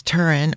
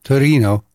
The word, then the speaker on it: Torino: Allora, we are very excited to tell you about the fabulous city of Torino, which is sadly referred to in English as Turin or Turin. However, I think it should be only pronounced in the beautiful name Torino. – Certo, Torino, without the roll of the R.